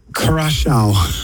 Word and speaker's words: Curaçao: originally from Cachao. Is that the right pronunciation? The Cachao in, Curaçao in the Caribbean,